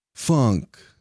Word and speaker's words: Funk: Bunkus, B-U-N-K-U-S, Bunkus. – Now, please tell me your last name, and please spell it. – Funk, F-U-N-K, Funk.